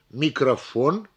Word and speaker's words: микрофон: Произнося слово «микрофон», мы проделываем то же самое с последним слогом, который является ударным.